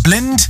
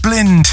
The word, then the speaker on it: BLND: I think I'm saying that right. I scrambled around everywhere on Instagram and online to try and find out how they say it. It's spelled B-L-N-D. And I think they put someone on their Instagram story saying it, so I'm copying that. Because if it's wrong, blame that guy.